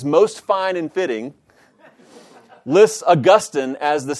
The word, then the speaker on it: Augustine: When the final syllable is dropped from the Latin name in forming the anglicized name, Augustine retains the accent on the penult rather than wrenchingly shifting it to the antepenult, as in the case of Augustine. He's serious about this. In this way, Augustine is closer to the original pronunciation pattern, and it thus constitutes a more natural and appropriate pronunciation.